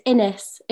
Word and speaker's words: Inez: So it's Innes. It rhymes with Guinness, which is...